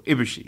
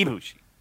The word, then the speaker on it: Ibushi: Is it Ibushi? Am I right? – Yeah, stressing okay. The first syllable, Ibushi. Yeah. – Ibushi.